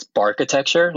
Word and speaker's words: Barkitecture: So his new show, how do you pronounce it? – I think it's Barkitecture, like architecture, but bark, because it's dogs.